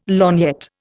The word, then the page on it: lorgnette: Indian